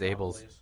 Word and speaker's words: Ebel: Yeah, is it E-B-E-L? – Yeah. – Yeah. – It's Weiss. – Abel's Weiss. – Abel's. Yeah. I think that you say it as Abel's.